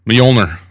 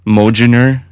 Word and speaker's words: Mjolnir: Proper pronunciation of Thor's hammer. – Mjolnir. – Okay. – Mjolnir. – Mjolnir. – Mjolnir. – Mjolnir. – Like Jardiner. – Yeah, it was usually whenever the J's kind of silent.